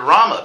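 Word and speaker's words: Ram (रम्): And that's directly stated in another verse describing the word Rama, because the Sanskrit root rum, not the Caribbean beverage, but the Sanskrit root, not to be confused. Okay. But it's pronounced the same way.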